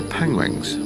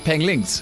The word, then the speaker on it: penguins: So now we have, he was actually doing a documentary on penguins and surprisingly nobody on the production team picked up that he was pronouncing the word penguins wrong. So I'm assuming that no one actually knew how to say the word penguins. Penglings. – Penguin. – Penguins. Penguin.